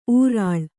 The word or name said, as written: ಉರುಳು